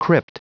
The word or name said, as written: crypt